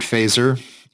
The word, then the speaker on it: Feser: I'm following a philosopher named Edward Fazer. He is at Fazer Edward. I see what he did there. And it's just F-E-S-E-R is his last name, and Edward is spelled how you think it is spelled.